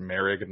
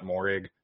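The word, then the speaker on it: Moehrig: I have learned that it's pronounced Merrig, not Morrig, so apologies.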